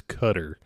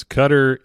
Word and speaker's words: Qatar: It's Qatar. Like, at least pronounce it correctly. Pronounce Let's all make an effort to pronounce these countries' names correctly, so at least they don't have that over us. But it's Qatar, it's Iran, it's Iraq.